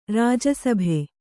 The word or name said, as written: ರಾಜಸಭೆ